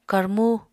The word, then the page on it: karmú: Apatani